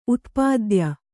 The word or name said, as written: ಉತ್ಪಾದ್ಯ